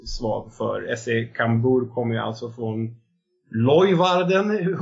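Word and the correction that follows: Leeuwarden: oklart hur, hur det uttalas. Leovarden till och med.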